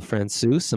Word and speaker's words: Francouz: Francouz.